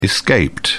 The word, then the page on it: escaped: British English